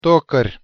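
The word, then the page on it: tokar': Tatar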